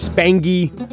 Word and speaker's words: Spangy: I must object to Corey Spangenberg's nickname. It is Spangy. I do not like when they mix the hard G with the soft G from the nickname to the actual name.